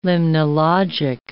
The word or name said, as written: limnologic